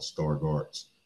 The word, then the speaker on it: Stargardt's: So, first of all, I'll start off, Marcus, just by saying, the eye disease that I have is called Stargardt's disease. It's spelled S-T-A-R-G-A-R-D apostrophe T-S or T apostrophe S.